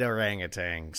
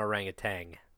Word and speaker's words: orangutan: It's not orangutan. There's no G. It's pronounced, there's a G on it, but there's no, when you pronounce it, Orangutan.